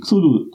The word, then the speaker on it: Cthulhu: Äh, eigentlich, äh, vermutlich sollte das eigentlich so etwas wie Cthulhu ausgesprochen werden, ja. Das war vermutlich Lovecrafts Aussprache.